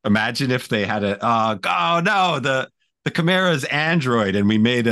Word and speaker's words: Chimera: Chimera? – Chimera, thank you.